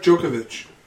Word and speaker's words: Djokovic: Oh, Novak Djokovic.